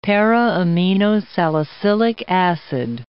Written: para-aminosalicylic acid

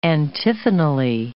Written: antiphonally

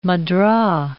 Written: mudra

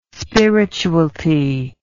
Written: spiritualty

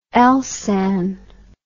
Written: elsan

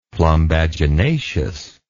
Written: plumbaginaceous

